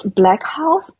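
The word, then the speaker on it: BLXCK HAUS: It's Black House, but it's spelled BLXCK and then house as in the German version of house. So HAUS.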